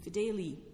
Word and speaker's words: Fideli: De Fideli, F-I-D-E-L-I. Now, where the reformed pronunciation would be Fideli, lawyers were saying Fideli.